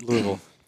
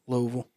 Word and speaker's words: Louisville: How far are you from Louisville? – An hour and a half. – How do you say it? – Louisville. – Yeah, there we go.